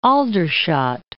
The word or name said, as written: Aldershot